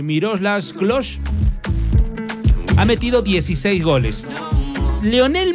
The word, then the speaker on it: Miroslav Klose: Pero hasta el momento, como máximo goleador de todas las copas del planeta, de todas las copas del mundo, es el alemán Miroslav Klose.